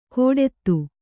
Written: ಕೋಡೆತ್ತು

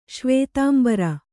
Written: ಶ್ವೇತಾಂಬರ

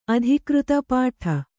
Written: ಅಧಿಕೃತ ಪಾಠ

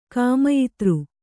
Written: ಕಾಮಯಿತೃ

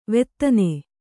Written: ವೇದನ

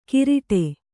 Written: ಕಿರಿಟೆ

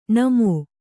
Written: ಣಮೋ

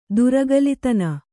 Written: ದುರಗಲಿತನ

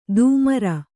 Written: ಧೂಮ್ರ

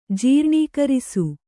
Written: ಜೀರ್ಣೀಕರಿಸು

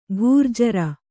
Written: ಖರ್ಜೂರ

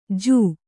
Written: ಜೂ

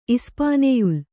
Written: ಇಸ್ಪಾನೆಯುಲ್

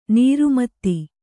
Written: ನಿರ್ಮದೆ

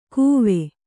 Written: ಕುವೆ

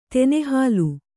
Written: ತೆನೆಹುಲ್ಲು

